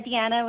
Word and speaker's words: Deanna: Hi, this is Deanna calling in from Brooklyn, New York. – Hello, Deanna. – Hi, is it Deanna? Am I saying that right? – Yeah, Deanna with a D.